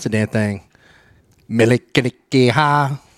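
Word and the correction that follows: Mele Kalikimaka: Oh, it's the right way to say Merry Christmas. – Yeah. – Yeah. – Yes. – Melikalikimaka. – Yeah, there you go.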